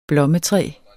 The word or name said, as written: blommetræ